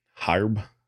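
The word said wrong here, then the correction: Hryb: Harb. – No, Larry, Larry Herb. Herb. Larry Herb.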